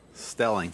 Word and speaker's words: Stehling: Is it Sterling? – Stelling. – Stelling. – Yeah, silent H.